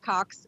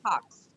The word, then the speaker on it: Cox: My last name is just Cox, C-O-X. My maiden name is Probst. – How do I pronounce it properly? The last name? – Your name? – My last name is Cox, C-O-X.